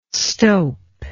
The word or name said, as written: stowp